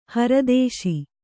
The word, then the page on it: ಹರದೇಶಿ: haradēśi